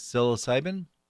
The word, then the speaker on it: psilocybin: and I'm going to mispronounce this, psilocybin, I guess. – Yes, that's right.